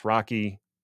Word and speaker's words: RoCE: And unfortunately, the implementations of RDMA over Ethernet, including Rocky, R-O-C-E, yes, it's pronounced Rocky,